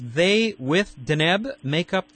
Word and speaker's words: Deneb: Deneb. At least that's how you told me it's pronounced. – Deneb.